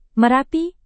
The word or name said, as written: merapi